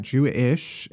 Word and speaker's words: Jewish: And then Jewish. Is that how you are pronouncing the title of the book? – Emphasis Jew-ish. On the ish.